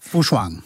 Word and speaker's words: Fu Shuang: Fu Shuang. Er det rigtigt udtalt? – Ja, det er det. – Godt, tak. – Fu Shuang.